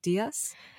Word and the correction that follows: diya: Yeah, make your own diya.